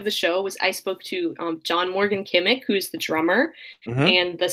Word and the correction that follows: Kimmock: Steve, yes, Kimmock. Is that how you say it? – Yes, that is Kimmock. The correct way to say it.